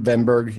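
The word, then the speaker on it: Wennberg: We have to pronounce the names correctly. – Ven Berg. – Oh, well, that's... – But that was pretty good. I'm saying for the American pronunciation, Ven Berg. Yeah, that's not too bad. – Okay, give us the proper one more time.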